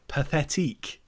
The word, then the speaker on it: Pathétique: So let's wrap it all up with the opera Pathétique.